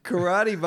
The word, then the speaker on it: Corradi: Karate, by the way, is spelled Corradi. – Okay. – Karate. That's a cool way to spell it, too. – Isn't that how you'd pronounce that? – She's like a hippie karate person.